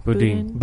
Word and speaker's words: Boudin: Was it, is it Boudin? How do you pronounce it? – Boudin.